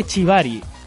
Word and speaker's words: shibari: A ver, un momento, esa pronunciación, ¿eh? – Shibari. – Shibari. – Es, muy moderno decir la palabra en japonés.